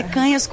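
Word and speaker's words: Cañas: Canhas, picanha, o cara nunca mais esquece, mas é Canhas com N com tio, e as redes são Ana, _ Canas.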